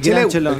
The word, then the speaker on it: Chelem: ¿El Gran Chelón? El Gran, El Chelem.